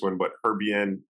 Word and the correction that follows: Hibernian: Football Club. Then going Hibernian.